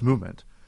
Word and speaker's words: MVMT: Movement Watches, spelled MVMT but pronounced Movement, was founded on the belief that style shouldn't break the bank.